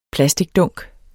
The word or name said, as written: plasticdunk